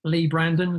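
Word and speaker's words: Leigh Brandon: Twitter is just my name, Lee Brandon. Lee is spelled L-E-I-G-H.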